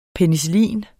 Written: penicillin

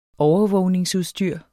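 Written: OVERVÅGNINGSUDSTYR

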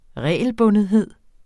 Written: regelbundethed

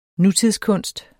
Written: nutidskunst

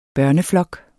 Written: børneflok

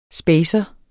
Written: spacer

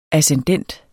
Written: ascendent

